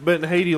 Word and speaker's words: Haiti: But as far as in Haitia, I'm probably butchering that.